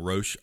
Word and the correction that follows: Rauch: R-A-U-C-H. She played Roush. – Melissa Roush. Mm-hmm.